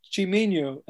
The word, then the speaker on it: Chiminyo: And then you're like, It's Portuguese, Brazilian, it's Chiminyo. And they go, Chiminyo. But, you know, it reads like Chiminyo in English, so.